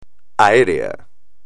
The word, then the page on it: aéreo: espagnol